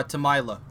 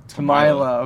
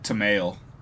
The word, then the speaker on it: Temaile: Uh, Temaile. – Temaile. Okay. Hmm. – Yeah, I, I, always said Tamale.